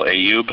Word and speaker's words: Ayub: My name again is Bill Ayoub, and that's spelled A-Y-U-B.